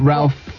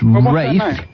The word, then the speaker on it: Ralph: It's like Ralph Fiennes. – Rafe.